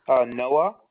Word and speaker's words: Noah: No, like Noah's Ark. Noah.